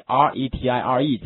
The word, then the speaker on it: retire: 这个 retire，这个 ire 读音哈，叫 ire，对不对？好，跟我读一下好吗？ire 是 i 和 er 两个词的组合哈，跟我读一下 retire。